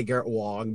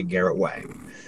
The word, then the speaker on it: Garrett Wang: I know how you say Garrett Wong, but it's pronounced it's spelled Wang, and it's funnier to say Garrett Wang.